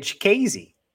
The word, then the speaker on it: Chikadze: Oh, dude, Chikadze.